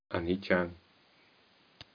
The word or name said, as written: aniccam